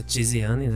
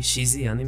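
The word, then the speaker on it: Chiziane: Então a Paulina, a, a pronúncia Tiziane, né? Eu falo Tiziane por uma questão de escolha, mas muita gente a chama de Xiziane mesmo. Paulina Xiziane.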